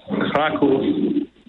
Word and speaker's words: Kraków: But, you know, as I said, we're in Krakow, Poland, commonly, you know, in Polish pronounced Kraków.